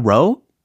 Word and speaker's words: row: Have you had a row? Have you had a row? 어, 근데 특히 엄마 수의 발음대로 최대한 따라해 본다면 Have you had a row? 이렇게 들리긴 했어요.